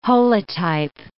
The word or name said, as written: holotype